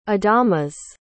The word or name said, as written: adamas